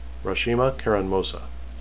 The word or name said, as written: rashima